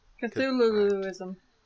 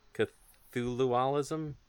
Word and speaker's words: Cthulhuism: Cthulhuism. – Cthulhualism? – That was it. – This is embarrassing. – How do you say your religion exactly? Is it just Cthulhu? – You had it the first time.